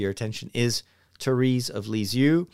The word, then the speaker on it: Lisieux: That is me trying to do an uh, like a, an English accent, an English pronunciation. If you pronounce it the way it is pronounced in France, where she's from, it would be Sainte Thérèse de Lisieux.